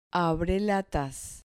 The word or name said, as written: abrelatas